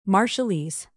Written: marshallese

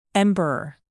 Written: emperor

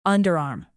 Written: underarm

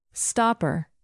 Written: stopper

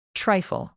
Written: trifle

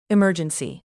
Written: emergency

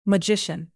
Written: magician

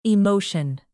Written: emotion

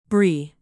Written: brie

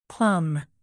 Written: plumb

